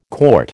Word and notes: CT: American English

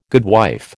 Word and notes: goodwife: American English

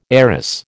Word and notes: heiress: American English

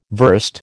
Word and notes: verst: American English

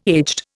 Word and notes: aged: Indian

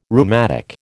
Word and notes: rheumatic: American English

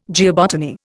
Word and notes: geobotany: Indian

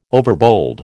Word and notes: overbold: American English

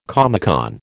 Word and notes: Comecon: American English

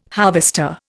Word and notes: harvester: Indian

